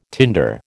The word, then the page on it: tinder: American English